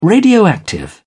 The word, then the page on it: radioactive: British English